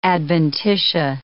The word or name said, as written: adventitia